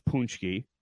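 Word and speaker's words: Pączki: And so Paczki is pronounced Puczki, and I'm going to have to apologize, though I'm 50% Polish myself.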